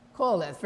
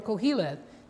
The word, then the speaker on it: Koheleth: Koheleth, very good. Koheleth, right, or Koheleth, depending on which pronunciation you prefer.